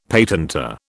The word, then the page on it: patentor: British English